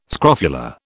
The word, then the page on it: scrofula: British English